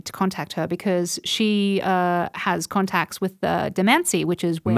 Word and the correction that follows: Manisi: Manisi. – How do you pronounce it? – Manisi. – Oh, you don't say the D? – The D is barely pronounced.